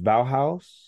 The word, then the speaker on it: Bauhaus: Is it pronounced Bauhaus? – Yes.